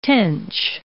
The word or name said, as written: tench